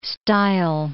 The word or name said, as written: stile